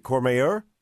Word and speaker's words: Courmayeur: Is it Courmayeur? – That's right, yes.